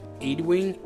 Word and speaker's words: Ewing: I always had a hard time pronouncing his last name. Ewing, I think is how he pronounces the name.